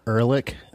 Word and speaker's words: Ehrlich: We're with Ariel Ehrlich.